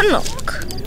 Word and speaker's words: knock: Knock. – The K is silent.